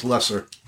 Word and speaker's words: Loesser: I found it's pronounced Loesser.